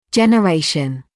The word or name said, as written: generation